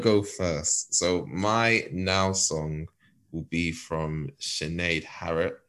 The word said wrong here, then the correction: Sinead Harnett: What the fuck was that? Harnett. – Harnett? I don't know it. See, I don't know anything. See, I saw it. I think I know how it's spelled, but you know when you say a word before you've ever said it? Sorry, Sinead. I like your music. Sinead Harnett.